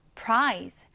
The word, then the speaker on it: prize: Z 발음을 살려주시면 됩니다. prize. 오른쪽에 같이 써볼게요. P-R-I-Z-E 읽을 때는 prize.